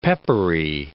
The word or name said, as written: peppery